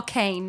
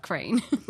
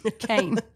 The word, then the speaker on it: Cayne: Oh, Kane, not Crane. Kane. – Kane the Crane. – But it's spelled like C-A-Y-N-E.